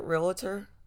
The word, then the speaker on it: realtor: Realtor? – Realtor. – Okay. Have I been saying it wrong this whole time? Okay. Sorry, y'all. So they call the realtor and... – It's not like Skeletor.